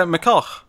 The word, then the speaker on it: McCullough: Or is that just a Scottish pronunciation? Alright, good. – Oh, yeah, McCullough. That's how you pronounce it.